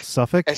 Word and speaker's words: Suffolk: Suffolk.